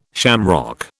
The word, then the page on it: shamrock: British English